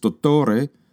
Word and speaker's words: dottore: Oh, there's another idiot who gives himself the same title, knowing quite well that doctor in his native language of Italian, pronounced dottore, just reflects a master's degree.